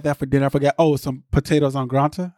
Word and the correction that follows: au gratin: Potatoes au gratin.